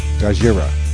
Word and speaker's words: Gojira: It's supposed to be pronounced like the Japanese pronounce Godzilla. Gojira. – Gojira. – It's spelled G-O-J-I-R-A.